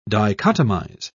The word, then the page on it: dichotomize: British English